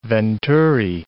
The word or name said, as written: venturi